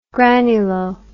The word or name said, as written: granulo